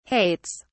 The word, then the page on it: hates: British English